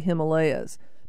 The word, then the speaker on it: Himalaya: That word is pronounced a lot of people say Himalayas, but Himalayas, is that Himalaya? Is that the correct pronunciation? – Yes. It's Hima means snow. Alaya means a boat, so you say Himalaya, a boat of snow.